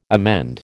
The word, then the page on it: emend: American English